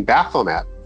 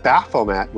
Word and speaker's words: Baphomet: And typically that would be not one T, it'd be two T's and an E for a strong T. So typically Baphomet would be how it would be pronounced.